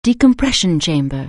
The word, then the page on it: decompression chamber: anglais